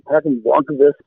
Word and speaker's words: Blomqvist: There's a part in here, Håkan Blom, is it Blomqvist? How do you pronounce his name? He's also a researcher, UFO researcher. – Oh, yeah, Håkan Blomqvist, Swedish researcher.